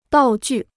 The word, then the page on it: 道具: dào jù